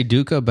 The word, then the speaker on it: dukkha: I say dukkha, but I think there's different ways to pronounce it.